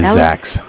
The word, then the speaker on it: Zach: My name is Zach. – Zach, spelled the other side of the alphabet. Z-A-C-H is how you spell it.